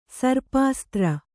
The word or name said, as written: ಸರ್ಪಾಸ್ತ್ರ